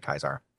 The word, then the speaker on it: Caesar: Like there's all kinds of stuff, but like you can tell if somebody is aligned with a legion by how they pronounce Caesar. – Yes. Because the faithful say Kaisar.